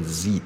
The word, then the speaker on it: Z: 那这样子的话，它是一个 L 音啊，它不是一个 Z 音啊，OK？它是 L，OK？L 音就是往外弹的，OK？啊，所以，哎，我也不知道是。怎么一回事？怎么在台湾大家会念成 li？反正就是 z，OK？有些人念 z，有些人念 z，我都可以接受。但是 li 就完全不对。